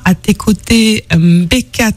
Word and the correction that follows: Mbeko: Tabula. C'est bien cela, hein, je vais pas écorcher le nom. – Si tu as écorché le nom, c'est Mbeko. – Mbeko, pardon, Mbeko.